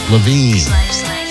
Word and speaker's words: Lavigne: Magic 98.9 with Avril Lavigne, but it's pronounced Levine.